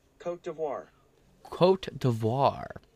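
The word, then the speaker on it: Côte d'Ivoire: Cote d'Ivoire. – There we go. The Prime Minister of Cote d'Ivoire.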